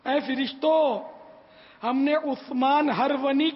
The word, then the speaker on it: ہرونی: یہ عموماً عثمان ہارونی بولتے ہیں لوگ, کوئی عثمان ہارونی بولتا ہے, کوئی عثمان ہرونی بولتا ہرونی پڑھتا ہے, تو یہ اصل تلفظ ہے عثمان ہرونی.